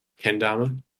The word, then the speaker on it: Kendama: So uh, how does a Kandama test. trip work? Kandama? How do you say it? – Kendama. – Kendama. Okay. Ken. You know, Ken. Like Ken. Kenny.